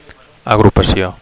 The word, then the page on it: agrupacio: Catalan